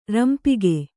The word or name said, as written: ರಂಪಿಗೆ